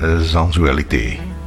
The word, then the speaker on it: Sensualité: En daarvoor hadden we nog Axelle Red en Sensualité. – Ja. – Zo moet je het uitspreken, schijnt.